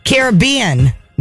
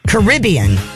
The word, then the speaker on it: Caribbean: You notice that Billy pronounces it Caribbean, not Caribbean, like many do. His pronunciation is actually the most common, and being from Trinidad in the Caribbean, you would know.